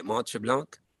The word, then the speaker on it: Mont Tremblant: Mont Tremblant? – Yeah.